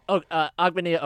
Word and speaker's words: Ogbonnia: Oklahoma's Ogbonnia Okoronkwo? – Agbenia Okoronkwo.